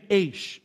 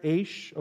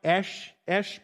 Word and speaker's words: Aish: The site is named Aish. It's kind of like the word beige. Do you hear it in there? Aish, okay, A-I-S-H. It's the word Hebrew word for fire. If you were a Hebrew, you'd pronounce it probably more like Esh, but we're not, so we call it Aish.